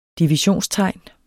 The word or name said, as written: divisionstegn